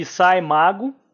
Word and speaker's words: SCImago: Eu encontrei uma plataforma na internet, uma página muito interessante, que é denominada de SciMago, Sci de Scientific mesmo, SCI, e Mago, como se escreve.